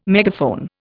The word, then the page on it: megaphone: Indian